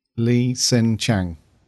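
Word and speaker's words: Li H'sen Chang: Lee Sin Chang. That's right. Yeah. It's a silent H, isn't it?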